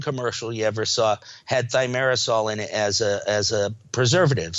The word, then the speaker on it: thimerosal: A mercury-containing preservative called thimerosal? How do you say that? – Thimerosal.